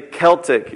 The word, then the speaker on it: Celtic: It's called The Celtic Way of Evangelism. It's not Celtic, by the way. That's a basketball team in Boston. It's actually Celtic is how they pronounce it over there.